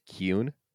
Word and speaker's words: Kuhn: According to the, I just discovered this week, according to the audio drama narration, it is pronounced Kuhn.